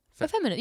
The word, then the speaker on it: effeminate: Feminine? Effeminate? – Effeminate, yeah. – Am I saying that right? Effeminate? – Yeah. Effeminate is a word.